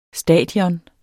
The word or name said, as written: stadion